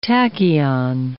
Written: tachyon

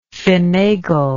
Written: fenagler

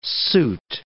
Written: suit

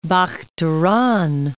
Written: Bakhtaran

